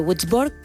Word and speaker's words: Würzburg: Venga, pues vamos a viajar, si te parece, hasta el 8 de noviembre de 1895 en el laboratorio de la Universidad de Würzburg. Creo haberlo pronunciado bien, si no es lo que hay. – Yo creo que sí, es una uno de alemán, ¿no?